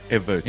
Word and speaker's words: Evert: It's Emmert. – Emmert. – E-V-E-R-T. Yeah, now you know.